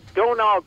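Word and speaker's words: Domhnall: Donal Gleeson is how you pronounce it. It's weird because there's an H and an M in there.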